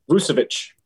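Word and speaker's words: Vučević: Vucevic. – Vucevic.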